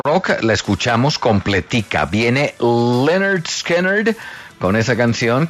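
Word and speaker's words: Lynyrd Skynyrd: En 1973, Leonard Skinner, que es un nombre muy jodido de pronunciar, a mí la verdad me da dificultad, le puso ese nombre a ese álbum. Se pronuncia Leonard Skinner.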